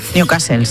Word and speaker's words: Newcastle: Continúa el goteo de focos de la enfermedad de Newcastle en, lo he dicho bien. – Newcastle, sí. – Bueno, medio bien.